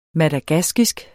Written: madagaskisk

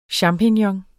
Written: champignon

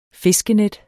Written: fiskenet

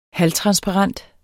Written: halvtransparent

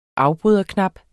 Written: afbryderknap

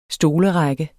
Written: stolerække

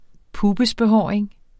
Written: pubesbehåring

